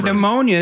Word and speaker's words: pneumonia: Pneumonia is super eloquent as a silent P, you know?